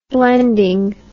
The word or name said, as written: blending